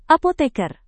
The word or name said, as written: apoteker